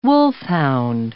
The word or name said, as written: wolfhound